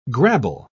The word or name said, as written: grabble